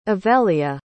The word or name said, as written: evelia